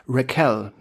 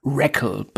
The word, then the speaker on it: Raquel: Und ich glaube, die grooven sich da erst später so ein bisschen darauf ein, wie Mrs. Ogmanik mit Vornamen wirklich heißt, nämlich Raquel mit Betonung auf der zweiten Silbe. – Sounds like a typo. – Genau. Ich glaube, sie kriegen es nie komplett hin. Sie bleiben im Endeffekt über die gesamte Serie bei Raquel, bei Betonung auf der ersten Silbe.